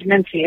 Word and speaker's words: mensae: They're called mensae. It's just the Latin word for, based on the Latin word for table.